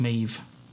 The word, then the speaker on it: Maebh: But so it was spelled Maeve is spelled M-A-E-B-H, so that's why I was calling it Maeve with a B, but it's apparently it's Maeve. And like it's pronounced M-A-V-E type.